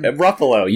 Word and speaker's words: Ruffalo: You meant to say it wrong, and you said it correctly. Ruffalo. You nailed it.